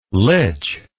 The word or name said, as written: lych